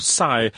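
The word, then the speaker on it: Cy: Kai or Sai? How do you pronounce that? C Y Sai.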